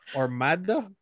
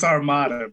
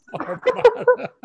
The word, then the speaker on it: Armada: He said that so Hispanic, dude. You said like each syllable really strong. – I don't get it. – It's just Armada, bro. Armada. Like rolled your R's and shit.